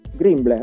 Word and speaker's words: Grimble: Greenball si scrive. Grimble, esatto, Grimble.